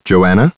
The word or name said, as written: Joanna